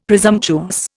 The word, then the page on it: presumptuous: Indian